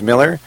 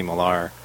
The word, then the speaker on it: Miller: It can be pronounced either way, actually. Growing up, it was Miller. But then, in my last year of high school, I actually started going to a different school, and there was another kid in that spelled it with an ER, so he was Miller, and they called me Millar.